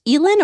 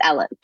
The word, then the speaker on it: Elin: Is it pronounced Elin or Ellen Hildebrand? Is it Elin or Ellen? – It's pronounced It's Ellen.